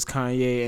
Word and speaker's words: Kanye: I'm like, I couldn't pronounce it right. I was like, is that Kane? Kane West? Who the hell is Kane West? So found out his name was Kanye, and then every single time he used to go around with his backpack.